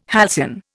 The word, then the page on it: halcyon: Indian